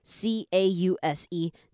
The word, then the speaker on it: because: 今天这个句子是比较多连音的部分啦，但是都不难。我们来看一下哈，你看到前面第一个字 because，你这边呢后面那个 c a u s e，你就把它想成 c u z， cause。Because 这样你会比较好念，然后后面那个 I've 这个比较没有太大的问题。我们来看到是 gotten，因为你要念快一点的时候，如果你把它念成 gotten 这样念出来，其实是不好念的哈。所以呢，在这边它就会被简化变成 gotten，好像是只有一个 g 跟 n 的感觉 gotten。